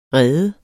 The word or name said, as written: rede